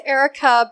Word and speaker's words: Arica: So it's Arica, but when I try to tell someone how to spell my name, I say Arica because it's spelled with an A. Because okay. – invariably, if I say my name is Arica with an A, they still spell it with an E.